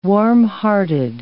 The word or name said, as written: warmhearted